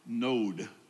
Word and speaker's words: Nod: This land called Nod, actually Node, is a better ancient pronunciation of the word.